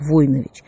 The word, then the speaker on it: Войнович: Кстати, сам Владимир Николаевич утверждал, что правильно произносить его фамилию как Войнович, с ударением на первый слог.